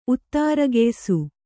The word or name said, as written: ಉತ್ತಾರಗೆಯ್ಸು